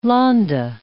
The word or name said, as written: Lahnda